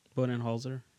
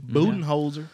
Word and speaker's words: Budenholzer: Mike Budenholzer. Is that how you pronounce his yeah. last name? – Budenholzer.